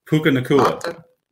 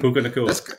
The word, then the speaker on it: Nacua: Puka Nacua. – Nacua. Wow, what a name. – Puka Nacua.